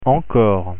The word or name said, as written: encore